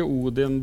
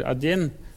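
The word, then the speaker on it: Adin (один): Det jo en helt vanvittig historie, fordi for det første, så, okay, på moderne russet, så sier de jo ikke Odin når de teller. De sier ikke Odin Dvatri, de sier Adin, så det uttales helt annerledes.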